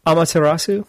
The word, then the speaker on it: Amaterasu: Okay, next we have Amaterasu. Did I pronounce it right? – Amaterasu.